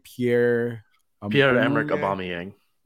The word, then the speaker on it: Pierre-Emerick Aubameyang: Pierre-Emerick Aubameyang.